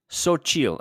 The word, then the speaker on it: Xochitl: I think it's, I don't know, Choro? Chiro? – Xochitl, apparently. Xochitl. There Xochitl? We go. Thanks, Google.